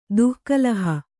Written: ದುಃಕಲಹ

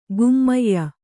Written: ಗಮ್ಯ

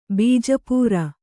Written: ಬೀಜಾಪೂರ

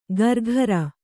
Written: ಗರ್ಘರ

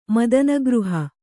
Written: ಮದನಗೃಹ

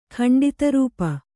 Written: ಖಂಡಿತರೂಪ

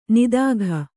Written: ನೀತಿಕೆ

